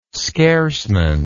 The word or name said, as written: scarcement